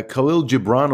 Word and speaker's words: Khalil Gibran: It's by Khalil Gibran or something.